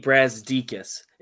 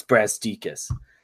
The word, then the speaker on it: Brazdeikis: The way I've learned it is if you just look at the name really closely, it's kind of pronounced how you'd think it's supposed to be pronounced. You just kind of take it slow. It's Brazdeikis.